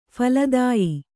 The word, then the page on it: ಫಲದಾಯಿ: phaladāyi